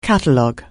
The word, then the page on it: catalogue: anglais